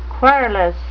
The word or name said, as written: querulous